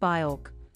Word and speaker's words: Björk: Despite him butchering the pronunciation of her name, which is pronounced Björk, Travis Scott's love for Björk fueled speculation surrounding his eagerly anticipated fourth album.